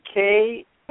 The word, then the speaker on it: Kitei: Her Lynne, and spell your last name Kitei. It's pronounced like a key and a tie.